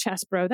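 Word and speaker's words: Chesebro: They're saying it's Chesbro. That's a dumb way to pronounce that. I'm going to keep saying Cheesebro until I'm told otherwise.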